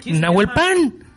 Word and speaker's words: Nawalpan: Es chileno, entonces que pronuncias así el nombre como si fuera británico o algo así. es Nawalpan.